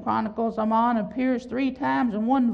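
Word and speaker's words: aman: Amen. Or Amon, if you pronounce it in Hebrew, but it's A-M-A-N.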